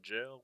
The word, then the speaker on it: gaol: Because even though it's spelled like G-A-O-L, it was pronounced jail, which is kind of weird.